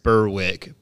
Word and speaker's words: Berwick: It's North Berwick. I know Berwick. That it is spelled Berwick, but it's Berwick. Yes. So, Cody, skip the W's over there. When I was in Ireland and I ordered a Smithwick's, they quickly corrected me. It's Smittick's. There's no W. There's no even in Ireland and Scotland, don't pronounce the W's.